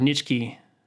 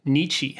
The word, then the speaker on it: Nietzsche: That was a Friedrich Nietzsche quote, probably. – I don't think you're saying that right. – Nietzsche. Is that how you say it? – There it is.